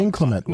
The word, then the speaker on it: inclement: Everybody was, you know, even with inclement weather. Is how you say it? – Inclement weather? – No, not intimate weather. You don't. Inclement weather. – No, let me try. You said it right.